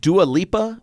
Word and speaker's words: Dua Lipa: Someone named Dua Lipa. Am I saying this right, AJ? – Yeah, no, you're saying it right.